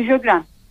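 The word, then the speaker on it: Sjögren: E, bunun üzerine e, bir doktorum bana e, Jögren e, yanlış telaffuz etmiyorsam eğer. – Evet doğru söylüyorsunuz.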